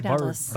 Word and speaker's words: Vardalos: Vardalos.